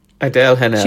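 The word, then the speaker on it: Adèle Haenel: Adele Hanel? Hanel? – Adele Hanel. – She plays Heloise. Okay, Adele Hanel.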